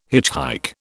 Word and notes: hitchhike: British English

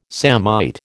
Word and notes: samite: American English

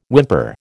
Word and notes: whimper: American English

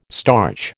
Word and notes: starch: American English

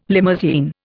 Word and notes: limousine: Indian

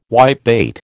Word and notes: whitebait: American English

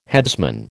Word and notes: headsman: American English

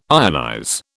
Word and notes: ionize: British English

